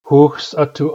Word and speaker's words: hooksatu: In my experience, almost no one pronounces the X right in hooks atu. Hooks atu means resting. X is a rare sound to begin with in New Channel. And in addition to that, it's in this word, it's in a three consonant cluster that's difficult to pronounce.